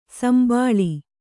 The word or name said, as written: ಸುಂಬುಳ